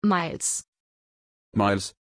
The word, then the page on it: Myles: German